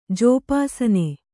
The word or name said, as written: ಜೋಪಾಸನೆ